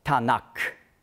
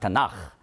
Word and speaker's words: Tanach: Dann gibt das Tanak. Aber man sagt Tanach, weil der Buchstabe Kaf, also K, wird weich ausgesprochen, wenn vorher ein Vokal ist. Darum sagt man Tanach.